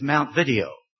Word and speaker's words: Montevideo: it's pronounced by the Virginians there, is Mount Video. Mount Video. It was named after Montevideo, I guess, in South America, Montevideo.